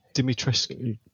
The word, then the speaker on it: Dimitrescu: But according um, to multiple Romanian sources, it should be Dimitrescu.